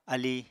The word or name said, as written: alíi